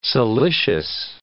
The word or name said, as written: siliceous